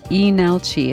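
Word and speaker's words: Leenalchi: E Nal Chi. It's got an L in front of it, but the L is silent. The first L, that is.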